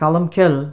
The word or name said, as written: Callumkill